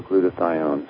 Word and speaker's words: glutathione: Well, there's two ways to pronounce it, the way you did, and then also glutathione.